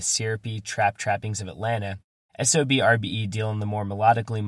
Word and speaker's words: SOB x RBE: Which brings us to Gangin, from Vallejo, California quartet SOB RBE. Yes, the X between the two halves of their name is silent.